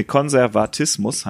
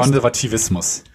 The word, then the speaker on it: Konservatismus: Konservatismus muss, nee, Konservatismus heißt. Konservativismus. Konservatismus. – Tivismus. Konser Nein. Konservatismus. Konservatismus. Äh, okay. Heißt, es ist, ist Wikipedia. Konservatismus, stimmt. Oh, ich habe die ganze Zeit falsch gesagt. Tut mir leid. Du kannst, bei, du kannst beides sagen.